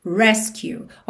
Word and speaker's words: rescue: Aqui, muita atenção, porque essa palavra resgate em inglês é rescue. rescue, ou seja, sílaba tônica é a primeira sílaba.